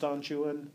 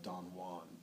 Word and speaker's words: Don Juan: By the way, it's pronounced Don Juan. It's spelt J-U-A-N. Byron absolutely and intentionally pronounces in a complete English pronunciation.